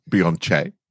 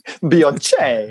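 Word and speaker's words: Beyoncé: It's pronounced Beyonce. – Beyonce.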